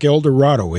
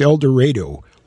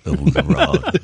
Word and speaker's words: El Dorado: Now, I know the word looks like El Dorado. It's pronounced El Dorado locally down there. – And London, and Liverpool, we call it El Dorado.